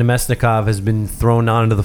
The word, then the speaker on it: Namestnikov: Namestnikov.